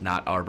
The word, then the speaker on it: Knotter: You know, they came out with Knotter Bourbon, which was MGP source whiskey, spelled Knotter, pronounced not our bourbon.